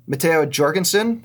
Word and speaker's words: Matteo Jorgensen: We had Matteo Jorgensen, that's with a J. There's video of him saying it when he's like 12, so I'm going to say that that's how you say it.